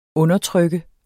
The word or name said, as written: undertrykker